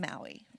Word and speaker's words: Maui: I mean, how could it not be pronounced Maui, right? Okay, Maui.